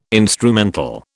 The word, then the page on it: instrumental: British English